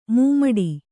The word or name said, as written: ಮೂಮಡಿ